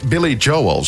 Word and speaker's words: Billy Joel: Zeg, zeg je Billy Joel of Billy... Billy Joel, zo spreek je het uit.